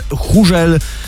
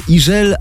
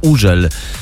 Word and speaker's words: Hugel: Hugel. No więc tak jest właśnie nieprawidłowo, a za to prawidłowo jest Iżel albo Użel, bo on jest Francuzem i we francuskim to H jest nieme.